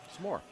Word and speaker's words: s'more: S'more. We say it differently. I have vowels. – There's no vowel between the S and the M. It's s'more.